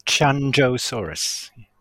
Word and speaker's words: Qianzhousaurus: Qianzhousaurus.